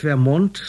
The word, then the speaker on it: Vermont: Daher hat Vermont seinen Namen und deshalb wird er auf der zweiten Silbe betont, nicht auf der ersten, wie es im Englischen korrekt wäre.